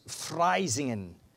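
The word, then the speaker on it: vrijzinnigen: And then you have another word, also a Dutch word, which is pronounced Freisingen, which is the word liberal, but not so much just liberal, but free, liberal thinking.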